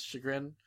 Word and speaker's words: chagrin: Much to Max's chagrin, I think. Oh crap! Yeah. Is that how I pronounce chagrin? – Yes. – Yeah.